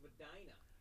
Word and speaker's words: Medina: It's pronounced Medina.